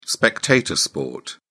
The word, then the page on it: spectator sport: British English